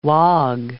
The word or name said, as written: wog